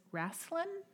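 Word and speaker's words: Raclin: And his name is Raslin, I think is how you pronounce it. It's R-A-C-L-I-N.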